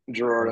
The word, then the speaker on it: Gerard: Is it Gerard? Did I get it right? Gerard. – Gerard, okay. that's correct perfect.